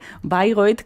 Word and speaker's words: Bayreuth: Bayreuth, que lo pronunciamos a la alemana, pero que se escribe, para los oyentes que no lo sepan, Bayreuth, está en Alemania.